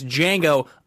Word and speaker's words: Django: It's Django Unchained. The D is silent.